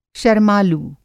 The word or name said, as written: शरमालू